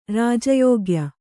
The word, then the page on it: ರಾಜಯೋಗ್ಯ: rāja yōgya